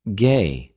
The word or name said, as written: γη